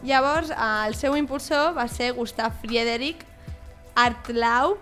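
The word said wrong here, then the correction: Hartlaub: no sé si ho pronuncio bé, segur que no. – L'H era... una mica aspirada. – Bueno. – Artlau.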